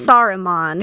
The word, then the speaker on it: Saruman: Saruman. – There you go. From Lord of the Rings. – Yeah, he's a bad guy. – Great. – With a name like Saruman, I don't know how you couldn't be.